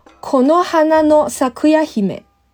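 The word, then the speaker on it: 木花咲耶姫: 木花开耶基，我们读作 konohana no sakura hime。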